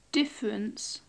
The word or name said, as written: difference